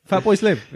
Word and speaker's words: Fatboy Slim: We can't even, it's got so late now, I can't even say Flatboy Slim. Fatboy Slim. There we go.